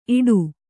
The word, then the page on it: ಈಟಿ: kannada; īṭi